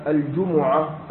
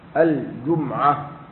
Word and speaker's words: الجمعة: وكلمة الجمعة أيها الإخوان هكذا بضم الجيم والميم، وقد تنطق بإسكان الميم، باب الجمعة الجمعة، وبعضهم ذكر فيها لغة ثالثة وهي الجمعة الجمعة على وزن الهمزة واللمزة فعلة. ويوم